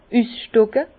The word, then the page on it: üsstocke: Bas-Rhin; Herrlisheim